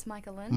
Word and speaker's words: Micahlan: Am I saying it right, or is it Micahlan? – It's Micahlan.